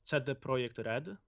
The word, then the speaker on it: CD Projekt Red: And actually, we say that it's CD Projekt Red. This is how you really pronounce that.